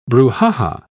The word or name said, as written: brouhaha